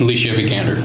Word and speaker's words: Alicia Vikander: And Alisa Vikander? – Alicia Vikander. – Okay. I was close. Vikander. – You got the hard part right.